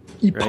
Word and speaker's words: epoch: Epoch. Right? Yeah. Epoch. – Yeah. – Uh, I'm trying to. Uh, that's how you say it, right? Epoch. It's E P O C H, and it's epoch.